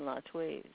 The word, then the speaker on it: Latwii: JP, you were referring to one of the sources that is spelled L-A-T-W-I-I. And that is generally pronounced Latwi on this end.